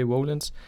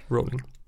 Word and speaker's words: Rowling: J.K. Rowlands. Rowling.